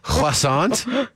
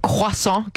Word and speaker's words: croissant: silent T will always get you. – Croissant. – Croissant. – Croissant. – Croissant. You don't, not the T at the end either. You really want to put that French croissant.